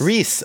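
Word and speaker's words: Rhys: Is it Rice Davies? – John Reese Davies. Reese. Reese.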